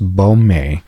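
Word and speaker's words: Boehme: Jacob Bohm. I've actually since learned it's pronounced Bohme. Bohme, yeah, that sounds about right.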